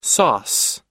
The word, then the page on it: sauce: American English